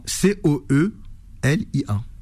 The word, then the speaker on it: Coelia: Et euh, ça s'écrit comment C O E L I A.